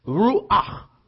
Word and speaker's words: ruach: is Ruha, I can't say it. But that's what it is. Is it Ruha? Ruach. Like you're hacking.